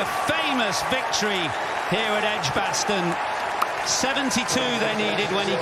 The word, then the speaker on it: Edgbaston: Edgbaston? – Yep, there you go.